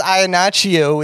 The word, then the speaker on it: Iannaccio: Yeah, my last name is Iannaccio with two C's, and we use a H sound in that.